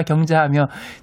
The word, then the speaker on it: 경자: 60년대 가장 흔한 이름 순자, 민자, 현자, 경자하며 제 이름은 갱, 상도 발음으로 이갱자입니다.